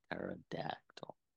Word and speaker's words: pterodactyl: Pterodactyl. No. – Because the P is silent. – There's a P in there? – Yes. It's actually pterodactyl. It's a P pterodactyl.